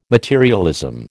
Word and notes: materialism: American English

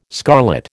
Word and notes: scarlet: American English

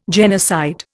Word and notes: genocide: Indian

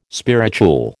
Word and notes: spirituel: American English

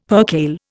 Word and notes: percale: Indian

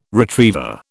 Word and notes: retriever: British English

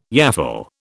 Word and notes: yaffle: British English

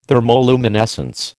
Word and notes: thermoluminescence: American English

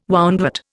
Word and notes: woundwort: Indian

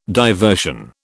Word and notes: diversion: British English

